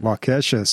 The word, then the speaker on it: Waukesha: Waukesha.